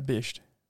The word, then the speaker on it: bisht: If you are asked to wear the bisht, how do you say it? – A bisht.